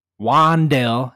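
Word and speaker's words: Wan'Dale: Wandale, as he corrected me, is his pronunciation.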